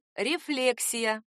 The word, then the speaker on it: рефлексия: Литературная норма — рефлексия. Ударение тут такое же, как в слове «рефлекс», оно не смещается.